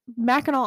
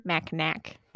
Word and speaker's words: Mackinac: Next, we have Mackinac Island itself, which Mackinac. I know. I always pronounced it Mackinac as a kid, and everybody would always look at me weird. I'm like, that's literally how it's spelled.